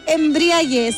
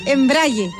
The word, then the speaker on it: embrague: A ver, vamos, No se dice embriague, se dice embrague.